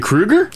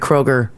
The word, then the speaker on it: Kroeger: Is he a Kruger? – He's a Kruger. Chad Kruger. – Yeah, speaking on a recent podcast, Kruger, or I can't even say it, because I've always said Chad Kroeger.